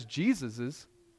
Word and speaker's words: Jesus's: They are standing as Jesus's, that's the right way to say that, as Jesus's representatives.